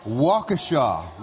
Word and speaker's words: Waukesha: You are from Waukesha, Wisconsin. Did I say that right? – Yep, that's right. – Waukesha.